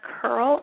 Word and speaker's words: Curle: My name is Melinda Curl, and my last name is spelled C-U-R-L-E.